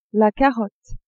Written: carotte

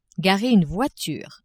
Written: garer une voiture

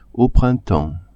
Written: au printemps

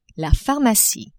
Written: pharmacie

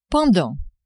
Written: pendant

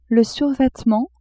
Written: survetement

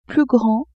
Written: plus grand

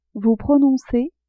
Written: vous prononcez

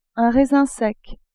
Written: raisin sec

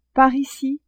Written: par ici

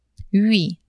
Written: hui

